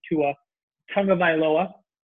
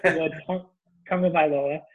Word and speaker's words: Tagovailoa: And Tua Tagovailoa, and I practiced that because I wanted to say it right. Tua Tagovailoa.